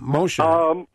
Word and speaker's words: Mosher: And, of course, our guest today, Randy Mosher, last name spelled M-O-S-H-E-R. And am I pronouncing it right? I hope I am. Mosher. – Mosher is normally what? – Yeah, Mosher.